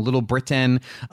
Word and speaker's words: Little Britain: I thought this monologue was written by two of the new writers, Nick Cockner and Brian McCaney from Little Brit. I always say it wrong. Little Britain.